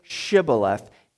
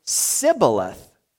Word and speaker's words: Shibboleth: Verse 6, "All right, say Shibboleth." If he said Sibboleth, because he couldn't pronounce the word correctly, they seized him and killed him at the fords of the Jordan.